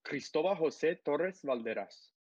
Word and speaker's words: Cristobal Jose Torres Valderas: I'm going to give you the very Hispanic way. It's Cristobal Jose Torres Valderas.